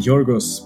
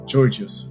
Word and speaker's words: Georgios: Well, first of all, Jason is calling me Georgios because he's a friend. That's how my name is pronounced in Greek. But everyone reading my name should read it Georgios, right?